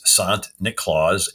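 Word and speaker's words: Sint Nicolaas: And the Dutch pronounce St. Nicholas, Sant Niklaus.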